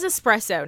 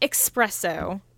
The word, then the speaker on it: espresso: It is espresso, not espresso. There's no X.